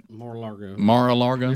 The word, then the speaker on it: Mar-a-Lago: Mar-a-Lago. It's kind of a mouthful. – Yeah, I don't. I wish you'd have named it something easier. – Mar-a-Lago.